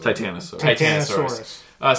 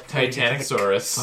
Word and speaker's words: Titanosaurus: Start to say Titanic, then say Saurus. – Titanosaurus. – Oh, now I want to see that version. Yes. Titanosaurus, where the boat is a dinosaur.